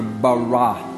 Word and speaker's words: bara: That word, it's pronounced in the Hebrew, bara.